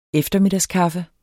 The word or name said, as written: eftermiddagskaffe